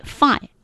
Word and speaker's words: fight: IGHT，这是写，但是读的时候绝不能读这个，读的时候要读音标，就拼出来 Fui Fight Fight，然后 GH 不发音，哎，他学过 Fight，他学过夜晚是 Light，夜晚是 Night。